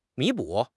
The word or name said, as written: 弥补